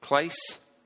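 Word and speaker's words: klas (κλείς): Okay, so it tells you there the transliteration, klase, how you say it. You say it klas, that's key.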